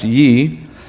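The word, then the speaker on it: ye (γῆ): The word translated land there in verse 45, it's ye in Greek. It's actually G-E, but it's pronounced ye.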